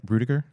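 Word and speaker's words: Ruettiger: So he was obviously a Notre Dame quarterback, and his teammate, forgive me if I, how do you say the kid's name? Rudy? Rudiger? – Yeah, Rudiger.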